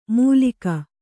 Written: ಮೂಲಿಕ

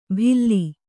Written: ಭಿಲ್ಲಿ

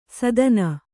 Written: ಸಾದಣ

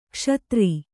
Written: ಕ್ಷುದ್ರ

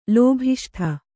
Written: ಲೋಭಿಷ್ಠ

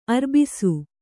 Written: ಅರ್ಬಿಸು

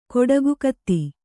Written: ಕೊಡಗುಕತ್ತಿ

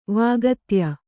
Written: ವಾಗತ್ಯ

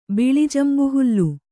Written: ಬಿಳಿಜಂಬುಹುಲ್ಲು

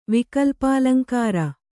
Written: ವಿಕಲ್ಪಾಲಂಕಾರ

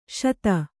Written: ಶಿತಿ

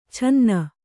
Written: ಛನ್ನ

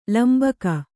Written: ಲಂಬಿಕೆ

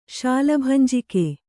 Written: ಶಾಲಭಂಜಿಕೆ

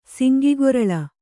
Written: ಸಂಕುಗೊರಲ್

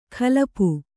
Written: ಕಲಪು

